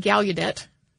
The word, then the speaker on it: Gallaudet: And this is essentially a student protest that changed the course of both Gallaudet University. I have also heard it pronounced Gallaudet with like the U sound. I've heard people pronounce it both ways.